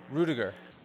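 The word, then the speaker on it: Ruettiger: But then also Rudy, I may not know the justice of pronunciation of the last name, Rudy Rudiger. – Rudiger.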